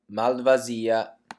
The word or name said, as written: malvasia